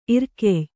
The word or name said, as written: ಇರ್ಕೆಯ್